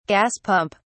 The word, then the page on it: gas pump: British English